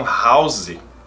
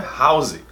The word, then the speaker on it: house: No Brasil, muitas pessoas pronunciam house. Até tem aquele seriado Dr. House, mas não é house, é house. House, o mesmo som daquela balinha, house.